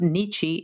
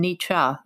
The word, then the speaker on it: Nietzsche: By the way, Nietzsche is pronounced Nietzsche in American English pronunciation, and Nietzsche in the German.